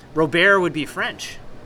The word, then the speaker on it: Robert: I actually learned it was Robert, not Robert.